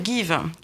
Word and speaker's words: Geev: Alors, je vous propose de découvrir une superbe initiative qui est l'application Give, qui s'écrit G-E-E-V. Mais il faut... – Ça fait Give. – Ça fait Give, donner en anglais à l'oreille.